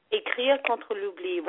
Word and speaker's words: Écrire contre l'oubli: Écrire contre l'oubli, Writing Against Forgetting.